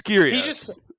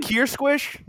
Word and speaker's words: Kyrgios: Yeah, no. It's Kyrgios.